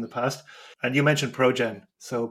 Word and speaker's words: Projen: By the way, it's spelled Progen as P-R-O-J-E-N. So it sounds like project, but it's Progen instead, which I always find it a little bit of a funny name.